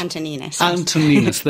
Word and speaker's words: Antoninis: This is from Manos Antoninis. Is that how it's pronounced? – Antoninis.